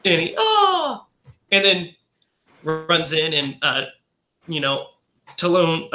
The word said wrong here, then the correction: Toulon: How do you pronounce his last name? Andre Talon, right? – You're doing good. – It's Toulon. – Andre Toulon.